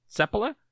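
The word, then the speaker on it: Seppälä: But I said to him, do you pronounce it Sepala? Because the accent with Finnish names is almost always on the first syllable. And he said, yes.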